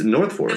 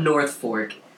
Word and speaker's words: Norfolk: I was thinking it was Norfolk. I would even give you that, but not North Fork.